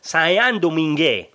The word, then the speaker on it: Saint-Domingue: Pues, por ejemplo, el nombre de la isla, en vez de Saint-Domingue, se pronunciaría algo así como Saint-Domingue. O,